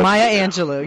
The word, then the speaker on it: Maya Angelou: And, you know, that one of the things that I heard Maria Angelou say, I'm not pronouncing her name right. – It's okay, but, Maya Angelou.